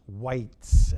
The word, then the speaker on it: Weisz: His name is spelled W-E-I-S-Z, but it's pronounced Whites, as I understand.